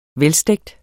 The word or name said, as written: velstegt